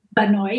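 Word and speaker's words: Bà Nội: It was Ba Now, is that how you pronounce it? Ba Noi? – Ba Noi, yeah.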